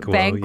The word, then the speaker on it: Banquo: We first meet Macbeth and Banquo, which what a name is that? Did I say it right? – Banquo. Yes.